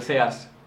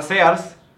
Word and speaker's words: Sears: Aquí es muy común, güey, que el mexicano le diga Sears. – Sears, sí. Pero en Estados Unidos no, güey, la pronunciación de, las, de la A y la A allá es muy diferente. – Entonces allá es como Sears. Sears.